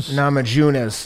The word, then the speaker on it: Namajunas: Namajunas.